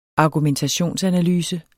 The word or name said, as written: argumentationsanalyse